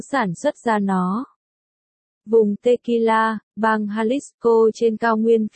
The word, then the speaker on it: tequila: Hứa Phương Chu, một, Long Thiệt Lan, tên tiếng Anh là Tequila. Phát âm như tequila, là một thứ rượu trưng cất có độ cồn cao truyền thống của Mexico.